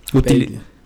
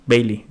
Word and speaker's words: Bailey: Bailey. – Bailey, gracias.